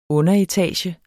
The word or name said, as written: underetage